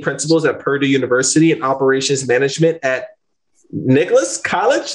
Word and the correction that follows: Nichols: Did I butcher that? Nicholas? – Nichols. – Nichols. Boom. Nichols College.